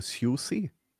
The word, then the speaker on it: SUSE: SUSE hat ja, glaube ich, so viele Namensänderungen durchgemacht wie kaum eine andere Distro und wird dabei immer noch gleich ausgesprochen. Das musst du auch erstmal schaffen. – Obwohl, also ich glaube, das kommt darauf an, von wo du kommst. Vielleicht sagst du irgendwo SUSE.